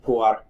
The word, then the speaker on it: Pu'er: Pu'er.